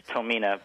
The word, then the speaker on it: Tomine: It's close enough. It's Tomina, but you know, I've heard it so many ways that nothing's really right or wrong at this point.